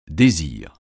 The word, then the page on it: désir: francais